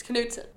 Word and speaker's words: Knudsen: And I know, as a Wisconsin native, you pronounce that Knudsen. – No, this isn't Norway. – Our sheriff had the name, that last name. It's Knudsen.